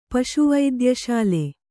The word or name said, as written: ಪಶುವೈದ್ಯಶಾಲೆ